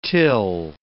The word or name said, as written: till